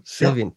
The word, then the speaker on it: Sylvian: Is that because you worked with Fripp and David Sullivan? – Sylvian. – Yeah. Damn, I always say his name wrong.